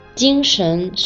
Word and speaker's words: 精神: 这两个词啊，看起来虽然是一样的，但是这个神字一个轻声，一个二声，读音不同，所以这两个词的意思和用法也就不同了。 – 原来是这样，那猪猪，精神是什么意思？